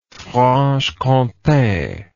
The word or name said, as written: franche comte